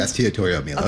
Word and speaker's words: Estiatorio Milos: Estiatorio Milos.